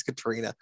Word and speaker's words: Caitriona: Starring Caitriona Balfe, Katrina. I don't know how to pronounce her name. I think it's Katrina Balfe, Judi Dench, Jamie Dornan, and Kieran Hines.